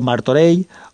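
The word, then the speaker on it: Martorell: Y, bueno, pues eso, que en valenciano eh, eso se pronuncia como una L o como una Y, si queréis, eh, y se diría pues eso, carbonei, o sabadei, o martorei o no sé, mm, cualquier otra cosa que se os pueda ocurrir.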